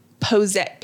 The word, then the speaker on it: Posay: Another one, La Roche Posay. P-O-S-A-Y is how it's spelled.